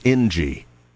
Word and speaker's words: Inci: It's actually Ingie. Spelled I-N-C-I, but she says you pronounce it as Ingie.